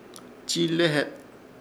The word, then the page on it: Chiilehet: Ishir Chamacoco